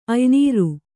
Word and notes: ಅಯ್ನೀರು: aynīru

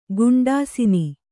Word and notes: ಗುಂಡಾಸಿನಿ: Kannada

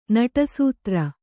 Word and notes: ನಟಸೂತ್ರ: Kannada

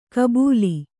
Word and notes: ಗಬ್ಬಲು: Kannada

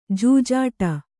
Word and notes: ಜಜ್ಜಾಡ: jajjhāḍa

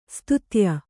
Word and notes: ಸ್ತುತ್ಯೆ: stutye

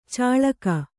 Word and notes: ಚಾಳಕ: cāḷaka

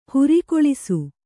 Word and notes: ಹೊರೆಗೊಳಿಸು: horegoḷisu

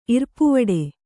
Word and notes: ಇರ್ಪುವಡೆ: Kannada

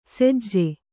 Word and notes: ಸೆಜ್ಜೆ: sejje

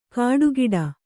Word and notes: ಕಾಡುಗಿಡ: kāḍu giḍa

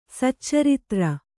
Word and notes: ಸುಚರಿತ್ರ: sucaritra; kannada

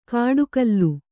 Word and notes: ಕಾಡುಕಲ್ಲು: kāḍu kallu

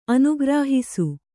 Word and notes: ಅನುಗ್ರಹಿಸು: Kannada

